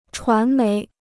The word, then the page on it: 传媒: chuán méi